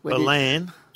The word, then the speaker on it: Ballan: Patrick and Ballin. – Ballan. – Ballan, mate. Come on. Ballan. It's not that hard to pronounce.